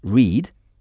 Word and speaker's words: read: Donc, R-E-A-D se prononce read ou au passé read.